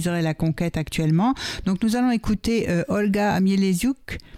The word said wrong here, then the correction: Mieleshouk: Je prononce bien. – Non, mais les choux. – Mieleshouk, voilà. Olga Mieleshouk,